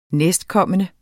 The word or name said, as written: næstkommende